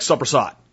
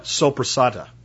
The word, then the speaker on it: sopressata: You know, if you're if you're if you're Italian, by the way, and you're from like New Jersey or New York, you call it like soppressata, right? And if you're an Italian that's from Italy, you call it sopressata. Okay, so don't tell me I'm pronouncing it wrong. It's that northeastern Italian thing that makes you guys say it that way.